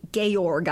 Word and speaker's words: Georg: It's spelled like George, but it's pronounced Georg, I guess.